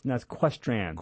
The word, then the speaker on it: Questran: Okay, well, I heard you talk about the Equestin, so that's something we could try. – That's Questran. – Questran. – Equestran. How do you say it again? – Q-U-E-S-T-R-A-N.